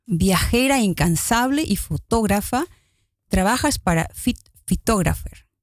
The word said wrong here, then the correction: Flightographer: Lo digo bien, flightographer.